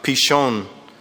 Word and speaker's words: Pishon: We call it Pison, but it's not Pison or Pison. It's a word that's actually pronounced something like Pishon.